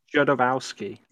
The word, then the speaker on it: Jodorowsky: Oh, Jodorowsky.